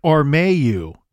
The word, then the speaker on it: Ormeau: And they placed the starting point at the intersection of Apsley Street and Armayu Avenue. And I'm going to spell those just in case I'm not pronouncing them correctly. That's APSLEY Street and OrmeaU Avenue.